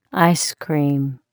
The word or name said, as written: ice cream